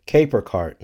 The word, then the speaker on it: Caper: It's from a company called Caper Cart, not Casper Cart. I'm sorry, I mispronounced that.